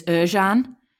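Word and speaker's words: Ercan: Traditionally, his name is Erjan. But Erjan, yeah. For me, it's always just, it's spelt with a C, so it's Erkan.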